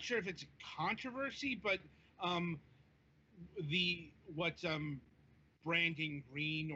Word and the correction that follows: Brendan Green: or Brendan Green, actually, I believe that's how that's pronounced.